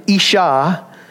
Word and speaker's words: Isha: She shall be called, he names her now, woman, Isha. First time that this is used, because she was taken out of man, Ish. The word Isha, you could spell it this way, I-S-H-A. And he is Ish, I-S-H.